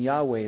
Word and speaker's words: Yahweh: More probably the pronunciation Yahweh is closer, but no one really knows how to pronounce it.